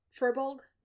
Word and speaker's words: Furbolg: the guy who played Marcus, the halfling wizard, the Furborg, that's not how you say it that way, Furbolg? – Furborg. I don't think I could say the race name. – The big forest people, the Furbolgs? – Yes.